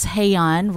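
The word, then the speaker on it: Hae-yeon: Oh, so my name is spelled a little funny, and I always say it's Hey-yeon rhymes with hey, gay yon.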